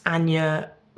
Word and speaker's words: Ania: I'm on Twitter at Ania, which is spelled A-N-I-A.